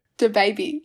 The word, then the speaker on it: DaBaby: DaBaby, like the baby, but...